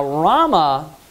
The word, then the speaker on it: Ramah: Now Ramah, or Rama, you can call it. I've heard it called, you know, referred or pronounced as both.